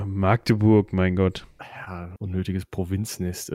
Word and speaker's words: Magdeburg: Magdeburg, Magdeburg, äh, wie spricht man das? Magdeburg. Also das, ich würde es mit T sprechen, also Magdeburg. Ich glaube, da reißt uns irgendein Magde Magdeburger, Magdeburger den Kopf ab. Ich meine mich daran zu erinnern, dass das A kurz ist, aber wir fragen einfach mal die Aussprache.